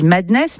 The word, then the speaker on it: MDNS: Ultra Violent, avec, je crois, on dit Madness, mais ça s'écrit MDNS. – Ouais, MDNS, Madness, Mad.